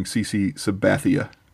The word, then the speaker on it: CC Sabathia: Featuring CC Sabathia.